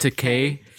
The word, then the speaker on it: Takei: It's actually pronounced Takei. – George Takei. Kay. I love George Takei.